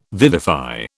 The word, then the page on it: vivify: British English